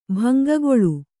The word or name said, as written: ಭಂಗಗೊಳು